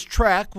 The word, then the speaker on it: Trek: So when your company's name is Trek, which rhymes with wreck, which makes you think of Mary Burke, a Trek wreck, as it were, then it's okay.